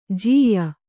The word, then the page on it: ಜಯ್ಯ: jayya